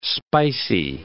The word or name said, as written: spicy